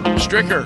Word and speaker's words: Stricker: He tees off 8:40 local time with Coach Bruce Pearl and Steve Stryker. He'll be doing Stricker. That'll be happening today. It's spelled Stricker, Rick. You spell Rick, R-I-C-K, don't you, buddy? Stricker.